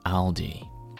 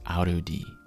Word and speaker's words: Aldi: It's actually a German supermarket, but its name is Aldi. And I remember one of my friends would always pronounce this Arudi.